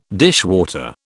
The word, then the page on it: dishwater: British English